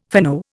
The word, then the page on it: fino: Indian